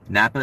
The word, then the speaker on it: Knappa: It was called Everyday Napa. Napa is spelled K-N-A-P-P-A.